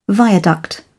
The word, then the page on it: viaduct: British English